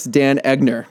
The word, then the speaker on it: Dan Egner: It is the GM of the Real Monarchs, Dan Egner.